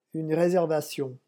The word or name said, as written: une reservation